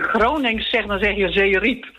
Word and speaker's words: Zeerijp: Nou, als je Zeerijp zegt, dan benadruk je de tweede lettergreep meer dan de eerste lettergreep.